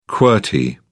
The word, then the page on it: qwerty: British English